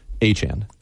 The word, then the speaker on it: Etienne: It was Travis ETN, and now it is Achan.